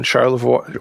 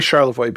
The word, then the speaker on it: Charlevoix: Anyway, Annabelle says, I'm from Montreal, but I live in Charlevoix. Well, I would say Charlevoix because that's how we would pronounce it in Michigan, but I bet in Quebec it's Charlevoix. a beautiful place up in the mountains.